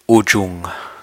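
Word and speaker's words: ujuṃ: Ujung. U-J-U. M with a dot over it. M with a dot over it is pronounced N-G.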